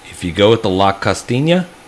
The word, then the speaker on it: Costeña: So we have La Costeña, C-O-S-T-E-N-A.